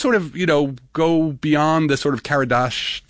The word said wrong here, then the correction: Kardashev: if I can pronounce it right, the Karadashov. I always get it. – Hold on, I've got the Kardashev? – Kardashev, thank you.